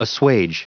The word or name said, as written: assuage